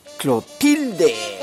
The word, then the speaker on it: Clotilde: ¿Sí, Clotilde? Se dice así, Clotilde.